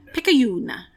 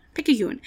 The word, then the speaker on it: Picayune: I had to look up how to pronounce it. Pickaxe Picayune. – Picayune. Picayune. The name means, the term Picayune means something of little value or worthless, which is a great name for a paper.